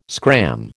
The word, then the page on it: scram: American English